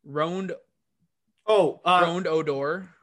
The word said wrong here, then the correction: Rougned Odor: Rougned Odor.